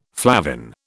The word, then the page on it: flavin: British English